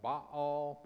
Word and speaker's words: Baal: She was a Phoenician princess and a devoted worshiper of their high god Baal, B-A-A-L. That's probably better pronounced Baal, but I'm just calling him Baal tonight.